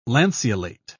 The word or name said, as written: lanceolate